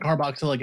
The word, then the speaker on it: carboxylic: Am I saying that right? – Carboxylic acid. Yep.